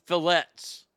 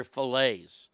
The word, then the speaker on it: fillets: She was calling them fillets. And I said, honey, the T is silent. They're fillets.